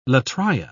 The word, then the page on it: latria: British English